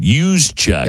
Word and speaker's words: Juszczyk: I'm probably going to say this wrong, but the NFL has fined San Francisco 49ers fullback Kyle Juszczyk, I believe is how you say it, Juszczyk. – Yes, that's correct.